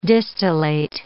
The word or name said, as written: distillate